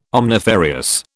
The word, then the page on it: omnifarious: British English